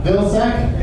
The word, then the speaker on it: Vilcsek: Vilsack. – Yes. How do you spell that? – Nazi. – V-I-L-C-S-E-K. – H-I-T-L-E-R.